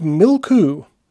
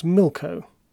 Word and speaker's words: milko: The cry of Milku. Well, it says Milku, but I think it's pronounced Milko.